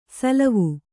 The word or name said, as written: ಸೋಲುವೆ